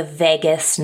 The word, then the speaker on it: vagus: Another really helpful piece of information that I learned was from Mel Robbins, and she taught about the vagus nerve. It's spelled V-A-G-U-S, and it sounds like Vegas, like Las Vegas.